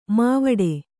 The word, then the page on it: ಮೈವಡೆ: mai vaḍe